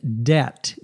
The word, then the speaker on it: debt: This episode is called Collecting Debt, D-E-B-T. Notice the B is silent when we pronounce the word.